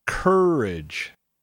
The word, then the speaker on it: courage: Make sure you get that R in there. Courage. And then there's a schwa sound after the R.